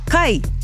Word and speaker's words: kai: Kai, food! And we better put inu in there as well, drink. So let's start with the word kai. K-A-I, said with the Māori vowel sounds.